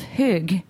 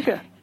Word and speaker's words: Hygge: And the subtitle is Embracing the Art of Hygge. Am I pronouncing that right, first of all? I'm really trying. – Almost. You're almost there. – Okay. Hygge. Hygge. Hygge. Oh, okay. One more syllable.